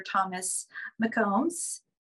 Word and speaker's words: Thomas McCombs: This is Dr. Thomas McCombs.